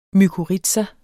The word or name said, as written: mykorrhiza